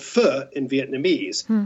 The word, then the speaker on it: pho: It's Q-U-A-Y, but it's pronounced K-E-E. But what about P-H-O? – That's an interesting one. I always thought it was faux until I started doing this book, and it's correctly pronounced fu.